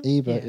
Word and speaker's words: Ebert: So film critic Roger Ebert, Ebert, is that how you pronounce his name? – Ebert, yeah.